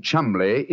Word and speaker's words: Cholmondeley: Being an American, he had no reason to know that the name Chumley is in no way pronounced the way it is spelt. – But you had never thought of that. Chumley. That name spelt C-H-O-L-C-H-O-M-O-N-M-O-N-D-E-D-E-R-L-E-Y.